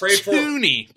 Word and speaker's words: Thuney: I believe it's Thuney, by the way.